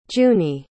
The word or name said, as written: junie